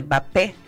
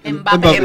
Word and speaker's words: Mbappé: de que había toda una vorágine de que Mbappé, ¿cómo se dice? Mbappé. – Mbappé.